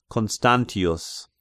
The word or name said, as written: Constantius